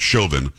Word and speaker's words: Chauvin: It is pronounced Chauvin, by the way. Those who are saying Chauvin and everything else, it's Chauvin.